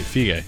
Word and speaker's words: Feige: No, he said it right, Feige.